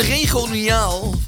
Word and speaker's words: regionaal: Een regionaal... Nee hoor, hoe spreek je dat uit? – Regionaal. – Heel goed.